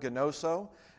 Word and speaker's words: gnoso: One of them is always easy for me to remember because it's a Greek word, gnoso, and I always think of it as I know so, gnoso. You know, I know so. It rhymes, that's how I remember it.